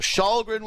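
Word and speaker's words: Källgren: I said I would pronounce his name right after after he had his quality starts with the Maple Leafs. So Shahlgren will start.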